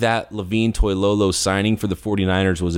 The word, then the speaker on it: Toilolo: And I think the 49ers in the offseason signing Levine Toilolo, uh, Levine, I'm sorry, Levine Toilolo. I've been calling him Levine for so many years, it's hard for me to correct it because for some reason I never said his name properly.